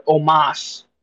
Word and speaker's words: Omos: So this one's going to be scratched from the record, but it was Bobby Lashley versus Omos, or Omos is how they actually pronounce it.